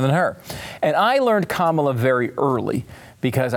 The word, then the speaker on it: Kamala: And then I realized there's only one way to really understand Kamala, and it's by just remembering that Kamala rhymes with the reaction to Kamala, which is like, nah.